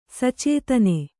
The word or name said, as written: ಸಚೇತನ